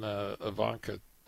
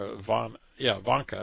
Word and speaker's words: Ivanka: this would be the Kushner is in Ivanka, or Ivanka, I said it right the first time, Trump's husband.